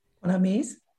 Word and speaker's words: Bonames: Bonames.